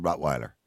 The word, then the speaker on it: Rottweiler: W in German is a V. Yeah. V sound. – Yeah, but you're not living in Germany, but you're living in Europe. So in Europe, we pronounce it Rottweiler.